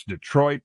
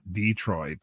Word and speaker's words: Detroit: First of all, they say you must learn to pronounce it correctly. It's Detroit, not Detroit. – Oh my gosh! Yeah. – if you say Detroit, we will assume you are from Toledo. uh, forget the traffic rules Detroit has its own version of traffic rules, and they basically are hold on and pray.